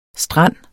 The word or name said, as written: strand